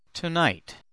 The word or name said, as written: tonight